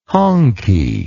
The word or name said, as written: honkey